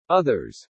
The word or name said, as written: OTHERS